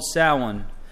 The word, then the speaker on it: Samhain: If you're ever doing the research, it's not how you spell it. It actually looks more like Samhain, but it's called Samhain, is how they would pronounce it.